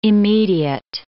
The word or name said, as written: immediate